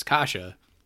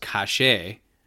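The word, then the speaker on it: Kaše: It's Kasha. And quickly, an actual Czech man corrected me and said it's actually pronounced cachet.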